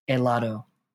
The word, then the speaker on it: helado: Do not pronounce an H in Spanish. It is silent. Helado.